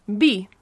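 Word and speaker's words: vi: So wine is B. – B. – Yeah. – As in like a B sound. – Like a B, yeah, literally just like a B sound. It's spelled V-I, but, you know, as we've said before, the V's are pronounced like B's, so you just say B.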